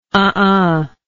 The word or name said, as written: uhuh